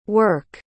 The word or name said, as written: werk